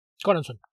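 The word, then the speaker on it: Choronzon: A mí lo que no me gustó es que, bueno, por un lado, ¿era Coronson o Choronson? – Coronson. Coronson. ¿Se escribe Choronson? Y se pone Coronson.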